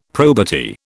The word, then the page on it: probity: British English